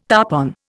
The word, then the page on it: tarpon: Indian